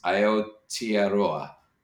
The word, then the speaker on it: Aotearoa: and I'll murder the pronunciation, is Aotearoa, the land of the long white cloud. Is that the right pronunciation? – Yes, yes.